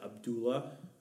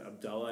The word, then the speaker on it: Abdallah: My name is, people pronounce it Abdullah because they spell it that way, but it's actually pronounced Abdullah, and it's actually supposed to be pronounced with a double A bend at the beginning, so it's hard to say. So in Arabic, it's Abdullah is how you pronounce it, which means you can hear the Allah at the end.